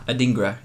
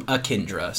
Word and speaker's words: Adingra: Adingra. – Yeah. – Adingra. Okay. – I butchered his name two episodes ago when I called him Akindra.